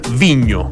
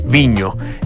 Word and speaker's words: Vigno: producen un vino que se llama Viño, se escribe Vigno, ¿ah? Viño, y que es, por supuesto, de la cepa Cariñán y de la zona central de nuestro país.